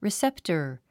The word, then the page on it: receptor: riséptər; リィセップタァ